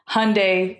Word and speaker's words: Hyundai: So they always tell us, just say Hyundai like Sunday.